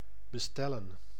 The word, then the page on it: livrer: /li.vʁe/